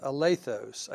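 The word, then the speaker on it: alethos (ἀληθῶς): I think it's pronounced alethos, A-L-E-T-H-O-S.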